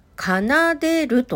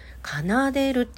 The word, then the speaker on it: 奏でる: で、実際にね、あの回答をインスタグラムでシェアをしてくれてて、奏でると読むそうです。楽しい出るね楽に出るで奏でるって読むそうです。